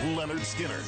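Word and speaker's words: Lynyrd Skynyrd: First night, the debut album pronounced Leonard Skinner, front to back, and more.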